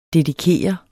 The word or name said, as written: dedikere